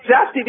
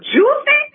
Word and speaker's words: Jussie: But when I saw his name, I didn't say Jussie because it's spelled J-U-S-S-I-E. I said, his mama named him Juicy? I called him Juicy.